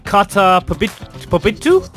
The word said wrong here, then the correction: karta pobytu: Pobitu? Kata Pobitu? Is that the correct pronunciation? – Karta pobytu.